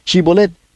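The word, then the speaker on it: shibboleth: Shibolet fue una palabra difícil de pronunciar para estos efrainitas. No podían pronunciarla con la H.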